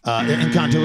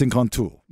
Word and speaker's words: Encanto: Encanto is wrong. – It's pronounced Encanto. – That is correct pronunciation.